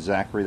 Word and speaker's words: Zachary: My first name is Zachary. That's spelled Z-A-C-H-A-R-Y. Last name is Lysak. That's spelled L-Y-S-E-K.